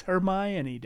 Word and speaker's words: Hermione: That's how I pronounced it when I was reading it to my kids until they corrected me. It's Hermione, Dad.